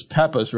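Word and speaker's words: Pepas: That's not Peepas, it's Peppas, right? – Peppas, I think so, yeah.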